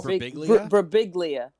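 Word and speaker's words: Birbiglia: There's a few other people, Mike Brigabaglia. Okay. I probably butchered his name as well. – Brabiglia. – Yes, that's it.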